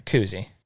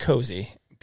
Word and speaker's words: koozie: A koozie. But I've also heard cozy. Beer koozie, beer cozy. – And how do you spell it? – K-O-O-Z-I-E.